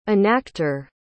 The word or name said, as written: enactor